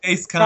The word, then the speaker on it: Dace Kime: D-A-C-E-K-I-M-E. – What's his name? – D-A-C-E-K-I-M-E. – Oh, well, yeah, I guess you would pronounce that the way it looks. – That's what we're going with. – Yeah, we're going with Dace Keim.